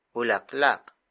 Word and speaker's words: bulaklak: Bulaklak. Bulaklak. Bulaklak is spelled as B-U-L-A-K-L-A-K.